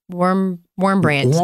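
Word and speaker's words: Wurmbrand: Warmbrand.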